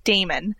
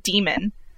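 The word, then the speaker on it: daemon: Okay, and can some listeners please correct me if I'm wrong, but I was almost positive that in the book it's pronounced like Damon, not demon.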